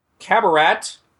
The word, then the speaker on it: Qabarat: Now the portal 3 would go to the largest city on Castrovel, which is Qabarat spelled with a Q. Qabarat.